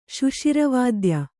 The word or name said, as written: ಶುಷಿರವಾದ್ಯ